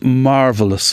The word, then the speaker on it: marvellous: Everything we did was always marvellous. Her favourite word, and pronounced with three distinct syllables.